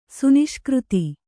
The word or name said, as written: ಸುನಿಷ್ಕøತಿ